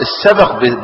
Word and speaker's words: السبق: ارجع لداوين اللغة، أمال السبق بمعنى المسابقة؟ السبق بسكون الباء، السبق، السبق بسكون الباء هو السباق. يبقى في فرق بين تحريك الباء وبين، يبقى السبق بسكين الباء يعني المسابقة.